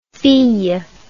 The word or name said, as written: fille